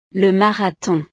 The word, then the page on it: le marathon: frances